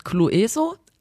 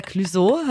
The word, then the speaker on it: Clueso: Und es ist bei mir passiert, bei dem Künstler, den ich immer falsch ausgesprochen habe, ich habe immer gesagt, ähm, Clueso. der heißt ja Clueso, habe ich dann gelernt.